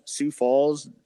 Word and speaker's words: Sioux Falls: Sioux Falls, Sioux Falls, South Dakota. – Yeah. Yeah. I mean, that's how you say it, but I don't remember it either.